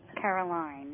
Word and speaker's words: Caroline: Okay, and your name is Carolyn? – Caroline. – Caroline. Okay. – Caroline. It's spelled L-I-N-E, Caroline.